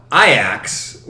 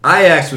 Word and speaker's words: Ajax: Ajax.